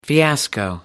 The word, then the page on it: fiasco: American English